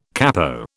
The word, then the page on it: capo: British English